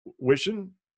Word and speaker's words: Wishon: Wishon. – Yeah. Oh, all right. Wow. So there's an emphasis on the H.